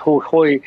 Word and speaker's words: Jujuy: Our story takes a look at a very remote province of Argentina, pronounced Jujuy, and we're seeing massive solar panels and mining projects to extract lithium.